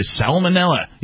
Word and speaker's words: salmonella: 46-year-old Tyrone has sued Frankfurt Christmas Market Limited over a food infection caused by salmonella. Yes, I pronounced the L in salmonella.